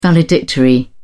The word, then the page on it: valedictory: British English